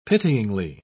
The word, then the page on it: pityingly: British English